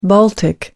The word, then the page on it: baltic: American English